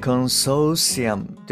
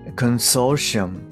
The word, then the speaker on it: consortium: これは、えー、コンソーシアムではなくて、 consortium。